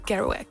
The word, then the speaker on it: Kerouac: Nee, want Jack Kerouac? – Ja. – Zeg ik het goed of zeg ik het echt als een Hollander? – Het klinkt goed, het klinkt goed.